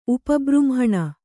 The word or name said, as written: ಉಪಬೃಂಹಣೆ